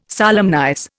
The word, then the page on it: solemnize: Indian